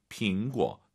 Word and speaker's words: 苹果: 모르셨으면 쓰시고, 苹果, 果 올라가면 안 되죠. 뒤에가 사성이니까 반삼성, 苹果 떨어지는 센스.